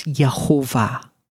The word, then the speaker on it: Yehovah: My name is Yehovah. Right? This is how God wants his name to be pronounced.